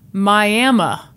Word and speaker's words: Miami: Just an FYI, Miami, Oklahoma is pronounced Miami. – What? – LOL. M-Y-A-M-M-U-H.